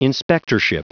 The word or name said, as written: inspectorship